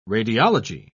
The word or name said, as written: radiology